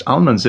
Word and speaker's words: almond: I've been saying to her, you know, when she heads into town, I say, can you get me some roast almonds? If there's anything that I love, it's almonds, particularly when they're pronounced correctly. – Almond. – The way that Kanye pronounces it, as if it has no L.